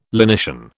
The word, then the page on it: lenition: British English